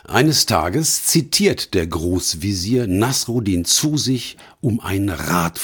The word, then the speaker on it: Nasreddin: Der Hauptdarsteller dieser Geschichte heißt Nasruddin. Meine türkischen und arabischen Freunde sprechen den Namen allerdings anders aus. Nasreddin, Nasruddin Hoxha oder ähnlich.